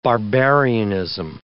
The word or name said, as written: barbarianism